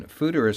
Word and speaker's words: foeder: Justin, fooder is spelled, not like it sounds, F-O-E-D-E-R, so what's with the spelling? – That's the Dutch spelling.